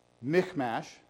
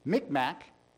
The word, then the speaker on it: Michmash: I looked how to pronounce it up, or pronounce it. It's sometimes it's Michmash. I've seen Micmac.